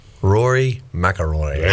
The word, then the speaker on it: Rory McIlroy: Rory McIlroy. – Oh, nice. And great job of pronunciation of the name.